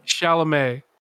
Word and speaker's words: Chalamet: You mean Timothy Chalamet? – Timothy Blanchet. Yeah, Timothy Blanchet. Isn't that how you say it? – Chalamet. – Timothy Chalamet, the god. – Blanchet? Bro, no. – Timothy Blanchet. – Have you been saying Blanchet this whole time? – I have been, yes.